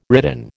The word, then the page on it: ridden: American English